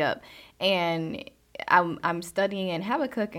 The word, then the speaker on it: Habakkuk: A lot of people see it and they give it its own its own name, its own pronunciation, but it is Habakkuk.